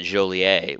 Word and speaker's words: Joliet: He didn't end up going to that high school, but uh, it's I just pronounce it Joliet, which is the French pronunciation, but uh, people in that town do not like it when you call it Joliet.